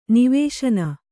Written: ನಿವಸನ

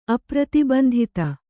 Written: ಅಪ್ರತಿಬಂಧಿತ

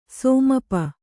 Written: ಸಮೀಪ

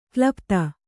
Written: ಕೊಲೆಪಾತ

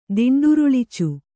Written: ದಿಂಡುರುಳಿಚು

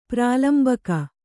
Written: ಪ್ರಾಲಂಬಕ

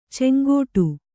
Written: ಚಂಗಾಟ

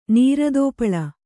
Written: ನೀರದೋಪಳ

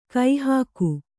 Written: ಕಾಹಿಗ

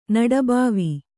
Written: ನಡಬಾವಿ

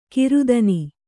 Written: ಗ್ರಥನ

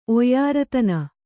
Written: ಒಯ್ಯಾರತನ

